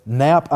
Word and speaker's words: Knapp: There was one case in particular, an Elizabeth Knapp, I believe is how it's pronounced. The spelling is K-N-A-P-P.